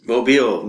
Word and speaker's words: Mobile: Mobile.